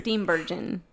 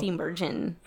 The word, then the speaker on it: Steenburgen: Steenburgen. – There you go. – Steenburgen.